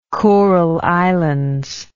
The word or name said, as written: Kurile Islands